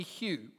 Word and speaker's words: Hugh: But mainly because kind of in the local dialect, you couldn't pronounce Hugh easily. So it was either Shu or Who or You.